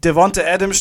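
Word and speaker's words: Davante Adams: Und ähm, also hier Lazar will er haben und äh, das Ding ist halt, Devonte Adams steht mit ausgestreckten Mittel... Ich habe das erste Mal seinen Namen richtig gesagt. Devonte Adams steht mit dem ausgestreckten Mittelfinger in,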